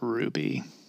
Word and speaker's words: RWBY: Not to be a total like weeb on you, but it's pronounced Ruby.